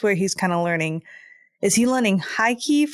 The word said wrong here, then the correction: haki: for the first time? I know Rayleigh hockey. Taught him a lot. – It's pronounced haki. Yes.